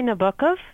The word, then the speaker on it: Nabokov: I would say Nabokov, but I don't mind how you pronounce it as long as people are reading it.